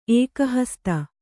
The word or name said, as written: ಏಕಹಸ್ತ